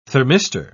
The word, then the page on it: thermistor: British English